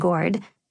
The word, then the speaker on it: gourd: When we reached Follow the Drinking Gourd, Victoria directed us to correct her pronunciation of gourd with a hard R.